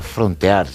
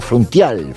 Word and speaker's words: frontear: Pero no hay que, no se pronuncia frontear, realmente los reggaetoneros lo pronuncian frontial, frontial.